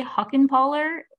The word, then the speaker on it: Huckenpahler: Huckenpahler, H-U-C-K-E-N-P-A-H-L-E-R.